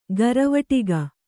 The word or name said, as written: ಘರವಟ್ಟಿಗೆ